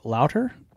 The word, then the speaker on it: Lauter: All right, well, tonight we are going to talk with the beer babe, Carla Jean Lauter. Did I get the last name right? – Lauter rhymes with water.